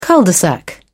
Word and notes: cul de sac: British English

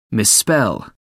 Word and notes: misspell: American English

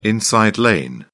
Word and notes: inside lane: British English

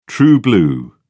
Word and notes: true-blue: British English

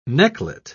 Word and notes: necklet: British English

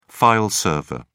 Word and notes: file server: British English

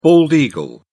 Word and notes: bald eagle: British English

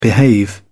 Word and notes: behave: British English

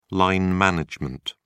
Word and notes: line management: British English

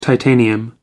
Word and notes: titanium: American English

